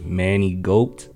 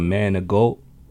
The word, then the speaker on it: Maingault: Early in school, a teacher had mistakenly pronounced his name Manny Goat instead of Maingault.